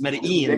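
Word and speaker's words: Medellín: Got to say it right. Don't say it like they said in Entourage, right? It's Medellin. – Exactly. – Medellin Mets fan. – Double L is J, right?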